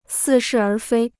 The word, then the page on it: 似是而非: sì shì ér fēi